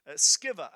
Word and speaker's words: Sceva: Sceva, I think, is how it should be pronounced. The seven sons of Sceva, although you probably pronounce it Sceva, and I have in the past, but I think it's Sceva.